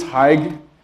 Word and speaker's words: Haig: My name is Haig. It's like Hi with a G at the end. It's a soft G, not a hard G.